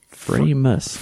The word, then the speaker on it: Framus: I've heard it said Framus. – Framus. Framus. So a little bit. I asked him what would the correct pronunciation be, and he... – I said Framus.